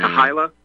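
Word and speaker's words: Kehila: Some people say it's pronounced Kahila, but it doesn't matter at this point. But that's K-E-H-I-L-A.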